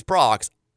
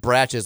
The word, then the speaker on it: Brach's: Uh, I also I just found out recently that I've been pronouncing, you know, the uh, B R A C H S, like the company that ha- makes the candy corn, like the little pumpkins that always. – It, it's Brock's. I said bratches like my whole life.